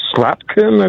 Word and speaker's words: Slotkin: It occurred to me I made two phone calls, one to Congresswoman Slotkin, I believe is her pronunciation.